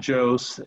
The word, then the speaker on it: Jose: I'm pretty easy to find on social media if they just search my name, Phil Jose, and the last name is spelled J-O-S-E.